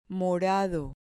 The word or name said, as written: morado